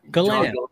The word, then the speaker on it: Galan: I mean, for a short last name like that, I've had so many different like pronunciations of it. I've had Galan, Galan, Galen. I don't even know other ones. Somebody really mentioned it. Yeah, but it's Galan. – Galan.